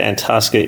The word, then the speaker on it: Antosca: say Antosca, some people say Antasca. Antasca is the correct pronunciation.